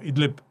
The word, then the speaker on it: Idlib: Aquí en España lo llaman Idlib y se pronuncia algo así como Idlib.